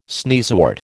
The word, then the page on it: sneezewort: American English